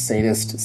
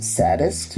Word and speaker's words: sadist: But in the English dubbing, they pronounce sadist saddest, yeah.